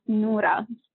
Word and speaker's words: Nura: The name is actually the way my name is pronounced in Arabic, which is pronounced Nura.